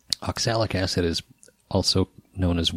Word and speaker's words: oxalic: Oxalic.